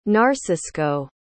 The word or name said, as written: narcisco